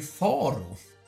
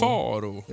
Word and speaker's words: Faro: Ja, det heter ju Faro. – Eller Pharaoh. Det beror på hur man, eh, när och var man uttalar det här. Men i USA så gick det ju mest under namnet Faro. F-A-R-O.